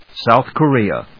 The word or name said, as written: South Korea